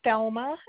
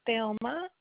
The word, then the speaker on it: Thelma: It is, my first name is Thelma, and it's spelled T as in Tom, H as in Henry. E is in elephant, L is in lemon, M is in Michael, A is in apple, Thelma.